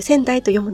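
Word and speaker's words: 川内: 仙台という字は三本川に内側の内と書いて仙台と読むのですが、時折ニュースに出てくる仙台原発のあるところです。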